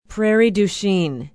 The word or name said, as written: prairie du chien